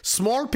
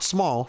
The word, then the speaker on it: smol: Smore peepee is spelled S-M-O-L. Smore. Not small. Small peepee.